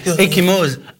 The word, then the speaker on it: ecchymose: Ecchymose. A-C-C-H-I-M-E-O-S-E.